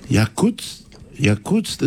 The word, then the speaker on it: Yakutsk: It's spelled Y-A-K-U-T-S-K, Yakutsk.